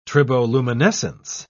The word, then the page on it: triboluminescence: British English